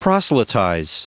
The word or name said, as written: proselytize